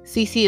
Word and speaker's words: Cece: Cece is spelled C-E-C-E on IG and Facebook.